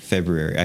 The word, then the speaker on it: February: We're about halfway through February. Is that how you say it? – February.